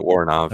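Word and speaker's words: Woronov: Mary, how do you pronounce her last name? Voronov? – How I say it, Voronov, okay, yeah.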